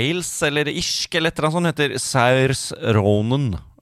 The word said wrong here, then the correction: Saoirse: Saoirse Ronan.